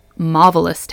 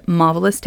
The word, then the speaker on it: Mavalus: It's called Marvelous Tape. It's spelled M-A-V-A-L-U-S, like marvelous, but with a mauve.